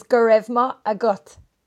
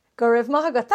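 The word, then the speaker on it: go raibh maith agat: In Irish, thank you is Gurevma agot. – Gurevma agot. – Gurevma agot.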